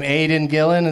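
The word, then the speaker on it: Aidan Gillen: Aiden Gillen, is that how you say his name? – Yeah.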